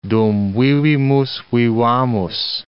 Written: dum vivimus vivamus

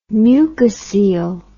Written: mucocele

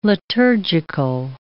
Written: liturgical

